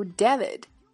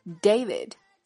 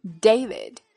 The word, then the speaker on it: David: David, long time no see. Look at me, packed on a few pounds over the holiday. 好，下面来逐个讲解。首先，人名 David，见一次我就会说一次啊。这个人名是很多中国人甚至老师都会读错的单词。字母 A 发它的本音 A，所以它从来都不读 David，而是 David。 David。